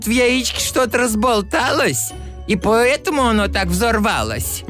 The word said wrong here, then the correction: взорвалось: Не взорвалось, а взорвалось. Ударение надо правильно ставить.